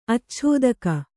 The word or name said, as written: ಅಚ್ಛೋದಕ